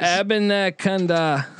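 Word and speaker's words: Abanikanda: Abinakanda.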